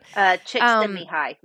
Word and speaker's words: Csikszentmihalyi: Csikszentmihalyi.